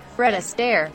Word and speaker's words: Fred Astaire: Fred Astaire.